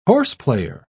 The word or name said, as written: horseplayer